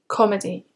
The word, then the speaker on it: comedy: Comedy, you've got two syllables. Stress the first syllable when you say comedy. Comedy.